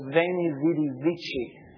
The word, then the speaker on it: veni, vidi, vici: And they say, weeny, weedy, weeky. And I say, great, now I've got to teach you another pronunciation. Because if you say that outside of a small gathering of people who are really into classics, they have no idea what you're talking about, and they're going to laugh at you. You need to know that most of the world says this, veni, vidi, vici, and it sounds great.